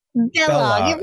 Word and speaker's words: Balogh: Balogh. It rhymes with Kellogg.